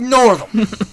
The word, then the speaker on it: Northam: And the governor, Northam, is that how you say his name? Northam? – Oh, yeah. Oh, yeah. Old Northam.